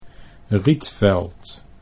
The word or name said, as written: Rietveld